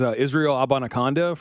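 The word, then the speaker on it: Israel Abanikanda: Another guy like is Israel Abanaconda from Pitt. – Oh, he nailed really. The pronunciation.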